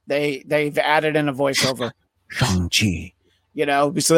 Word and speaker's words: Shang-Chi: And by the way, on a side note, from what I read, it is pronounced Shang-Chi, not Shang-Chi.